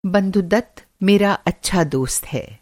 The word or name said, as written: बंधुदत्त